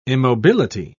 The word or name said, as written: immobility